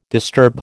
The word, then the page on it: disturb: American English